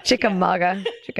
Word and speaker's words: Chickamauga: Did I say that right? – Chickamauga. It's a Cherokee word.